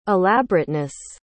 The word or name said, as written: elaborateness